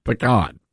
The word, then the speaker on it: pecan: Now, if you come at me with a pecan pie or a pecan pie, I'm going to turn you away because it's a pecan pie. That is the correct pronunciation, pecan.